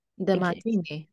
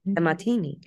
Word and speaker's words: DeMartini: DeMartini. – DeMartini, like the drink.